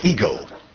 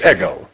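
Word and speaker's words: ego (ἐγώ): In English, it's pronounced ego. The word in Greek is ego.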